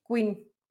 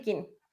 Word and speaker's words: Quinn: Eh, Harley Quinn, pero esta no es Queen de reina. King. Harley Quinn, esta es Harley Quinn.